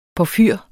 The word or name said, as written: porfyr